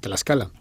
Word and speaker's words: Tlaxcala: Y ya has visto que, bueno, me ha resultado un poco difícil decir Tlaxcala. – Bueno, es complicado porque la TL pues no la pronunciamos en España, pero en México se utiliza mucho y bueno, pues así es como se llama, Tlaxcala.